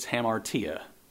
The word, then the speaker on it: hamartia: The Greek is not easy to say, but it's hamartia. And it's spelled in the English, H-A-M-A-R-T-I-A, hamartia.